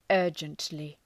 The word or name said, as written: urgently